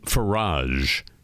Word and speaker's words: Farage: Well, a notorious member of parliament, Nigel Farage, F-A-R-A-G-E, rhymes with Keldomage,